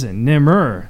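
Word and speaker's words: Nemr: Our guest is Nimur.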